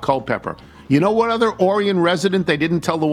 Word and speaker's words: Orion: Orion is how it's pronounced, right? Orion.